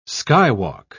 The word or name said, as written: skywalk